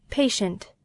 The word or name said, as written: patient